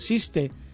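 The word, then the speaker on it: Císter: El curioso nombre del carnet necesita una explicación, y es que es un juego de palabras que recuerda la pronunciación en catalán de la palabra Cister. El 6 es sis en catalán y la T se pronuncia T, es decir, siste, que es como suena en catalán la orden monástica.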